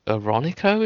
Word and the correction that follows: Orinoco: is that how you say it? – Orinoco. Oh, well, I'm not going to keep embarrassing myself. – Yeah, it's Orinoco.